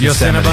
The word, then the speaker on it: Yosemite: Menace. – Menace. – You said me. – You didn't say it right. You didn't say it right, but we know what you meant. – Yeah. – Yes. Yosemite.